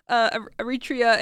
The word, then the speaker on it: Eritrea: I said that wrong in another episode. Eritrea,